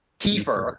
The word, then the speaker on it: Kiefer: Is it, if I'm saying it right, is it Kiefer or Kiefer? – Kiefer, okay. It's Kiefer.